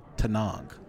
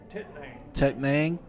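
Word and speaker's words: Tettnang: The hops are Saaz and Tanang. Did I say that right? – Tetnang.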